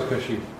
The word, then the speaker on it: Kashif: Kashif Khan, did I say it right? – You're close. – Close? – You're almost there. – What is it? – Kashif. But guess what? I grew up as Kashif.